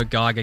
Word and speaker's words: Geiger: Giga counter. G-E-I-G-E-R. I believe that's the pronunciation. Geiger. No, Geiger counter. – G-E-I-G-E-R.